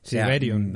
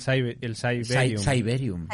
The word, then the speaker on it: Cyberium: Siberium, mm, el, Cyberium.